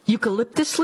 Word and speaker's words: eucalyptus: The only food koalas eat are eucalyptus leaves, fruit and nuts, insects and rodents. – Oh, I believe those eucalyptus, is that? Am I saying that right? Eucalyptus leaves. – That's yes. – Correct.